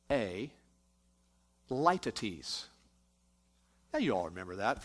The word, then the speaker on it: litotes: Looks like the word litotes, L-I-T-O-T-E-S. But it's pronounced litotes.